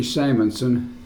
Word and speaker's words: Simonson: Archie Simonson, S-I-M-O-N-S-O-N.